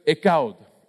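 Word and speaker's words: ekaud (אחד): es una palabra hebrea que se pronuncia ekaud, ¿ok? Ekaud, y así se escribe.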